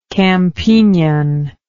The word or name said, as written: campignian